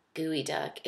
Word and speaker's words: geoduck: Kristen Bell's doing raw gooey duck. It's spelled geoduck, like geodude. And don't know why it's pronounced gooey,